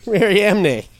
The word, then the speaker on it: Mariamne: I'm gonna spell it. – Maria. – But then M N E at the end. – Mariamne. – Holy fuck. – Mani. Mariamne. – Mariamne. – That's what it is.